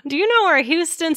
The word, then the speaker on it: Houston: H-O-U-S-T. Oh, yeah. T-O-N. – Okay. It is not like the state of Texas, guys, like the city in Texas. It is Houston Street. So important, because if you say it wrong, people know you're a tourist.